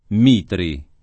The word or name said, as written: Mitri